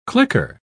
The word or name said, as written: clicker